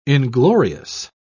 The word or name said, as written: inglorious